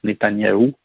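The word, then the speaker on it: Netanyahu: qui se prononce Netanyahou en anglais.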